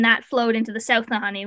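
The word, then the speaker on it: Nahanni: How do you pronounce it? Nahani? – Yeah.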